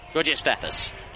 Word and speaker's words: Georgios Stathis: And and I really like, if this is how I pronounce it correctly, Georgios Stathis. Yeah. – Georgios Stathis.